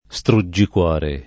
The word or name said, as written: struggicuore